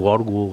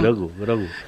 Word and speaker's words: Grogu: Que dius tu Gorgu, Gorgri. – No sé com li diuen. – És de Gorga. – Grogu, no? – Grogu.